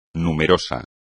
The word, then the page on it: numeroso: Spanish